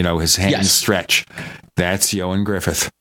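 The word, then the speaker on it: Ioan Gruffudd: And his name is pronounced Yoan Griffith. But if you look at the way it's spelled, and he spells it in the original Welsh, there's no way to figure that out.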